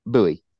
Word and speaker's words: Bowie: Do you say your last name Bowie or Bowie? – Bowie. – It is Bowie, just like Bowie. Okay. – Yes. – Because in Texas, we don't say Bowie. We say Bowie.